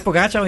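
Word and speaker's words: Pogačar: Je zegt altijd heel mooi Pogacar. En op de NOS hebben ze het over Pogacar. – Maar het is, maar het is Pogacar. Ik heb hem zelf gevraagd.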